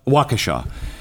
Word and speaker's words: Waukesha: And you know, we saw it in um, uh, what is it? Washaga? Uh, no, how do you say the name in Waukesha? Waukesha.